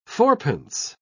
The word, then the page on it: fourpence: British English